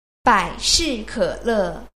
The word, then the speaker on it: 百事可乐: 百事可乐.